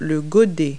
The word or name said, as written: bucket